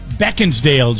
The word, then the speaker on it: Beckinsale: Beckinsale, Jeff. That's how you're supposed to pronounce it.